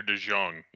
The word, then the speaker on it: DeJong: Is it DeJong or DeJong? Is it a soft J or a Y?